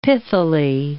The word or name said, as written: pithily